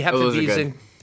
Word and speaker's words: Hefeweizen: And the other one that I got into this week, and I believe I'm pronouncing this right, is the Hefeweizen. The Hefeweizen, yeah, very good.